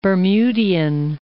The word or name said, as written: Bermudian